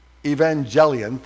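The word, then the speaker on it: Evangelion (εὐαγγέλιον): The word gospel, evangelion, thank you. Now that I know the U is pronounced like a V, it's not E-U. Evangelion.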